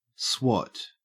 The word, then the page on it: swot: Australian English